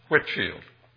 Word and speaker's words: Whitefield: His name is spelled like Whitefield, but we don't pronounce it as White. We say Whitfield.